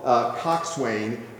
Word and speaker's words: coxswain: It's spelled coxswain, but it's pronounced coxswain.